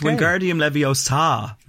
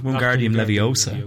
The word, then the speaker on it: Wingardium Leviosa: It's Wingardium Leviosa, not Wingardium Leviosa.